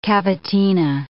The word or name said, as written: cavatina